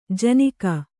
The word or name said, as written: ಜಿನುಗು